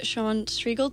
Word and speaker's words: Sean Striegel: Um, VP Sean Striegel, did I say that right? – Sure.